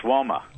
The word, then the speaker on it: WOMA: What is it, WAMA or WOMA? – It's WOMA.